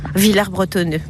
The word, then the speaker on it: Villers-Bretonneux: Je viens à Villers-Bretonneux depuis plus de 20 ans. – Vous savez qu'on ne prononce pas le S ici. Hein. – Villers-Bretonneux, pardon.